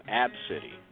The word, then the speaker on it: ABCDE: Ab City is spelled A-B-C-D-E. That's wow. – Her first name. Her first name is A-B-C-D-E.